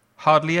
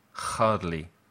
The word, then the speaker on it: hardly: Hardly ever. La H no se dice desde la garganta. No se dice hardly. Se dice hardly. Imagínate que estás limpiando las gafas.